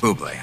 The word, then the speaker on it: Bublé: She's right, Michael Bublé. It's pronounced Bublé. I know, thanks.